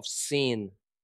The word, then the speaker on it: Sin: 他想要教导的一切，现在是他们该继续前进到训的旷野 The Wilderness of Sin 的时候了。请注意，我刚才念这个旷野的名称，不是念作 S-I-N 罪的音，而是念作 S-E-E-N Sin 的发音。